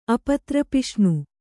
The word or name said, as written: ಅಪತ್ರಪಿಷ್ಣು